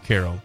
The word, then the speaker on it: Cairo: So, Carroll, is it C-A-I-R-O? – Yes, it's spelled like Cairo, Egypt, but it's pronounced Carroll.